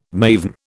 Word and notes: maven: British English

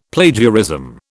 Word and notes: Plagiarism: British English

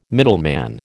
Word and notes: middleman: American English

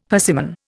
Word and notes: persimmon: Indian

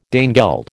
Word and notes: Danegeld: American English

